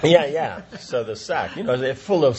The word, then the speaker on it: sac: And it's even spelled like the French, you know, sack. S-A-C, sack.